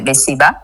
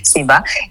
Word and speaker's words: Seba: Donc, il suffit juste d'aller sur toutes les plateformes et de regarder les albums Seba, c'est mon nom, Seba.